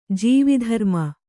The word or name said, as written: ಜೀವಿಧರ್ಮ